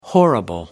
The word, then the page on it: horrible: American English